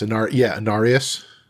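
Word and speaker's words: Inarius: And is it her and Anarius? Is that how you say his name? – Yes. Anarius.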